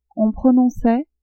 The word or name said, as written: on prononçait